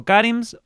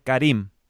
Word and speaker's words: Karim: ¿Es Karims o Karims o es Karim? – Bueno, mi nombre artístico es Karim. Mi nombre de pila es Karim. Es un nombre árabe. – Ajá. – Se escribe K-A-R-I-M.